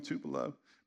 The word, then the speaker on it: Tupelo: Anybody here from Tupelo, Mississippi? That's how they say it, Tupelo. add another syllable on the end.